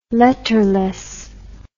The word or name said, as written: letterless